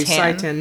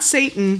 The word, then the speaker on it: seitan: I always mispronounce that. Thank you. Seitan. Not Satan. Seitan. – No, not Satan.